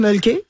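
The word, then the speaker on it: Mölkky: Alors, ça s'appelle le Mulky. Ça, ouais. On prononce Mulky. – Ouais. – Et c'est M-O-L-K-K-Y.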